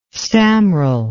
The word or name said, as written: staumrel